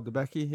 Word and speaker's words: Debicki: Elizabeth Debicki. – Oh, Debicki, yeah.